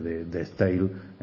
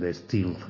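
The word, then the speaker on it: De Stijl: que me enteré hace poco que se dice de Steel, después de toda la vida intentando pronunciar el de Steel ese con la J.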